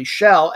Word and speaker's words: schele: It actually came from an Old English word called, I'm going to pronounce it simply shell, S C H E L E, which was a word for like a little tiny cabin or a fishing hut.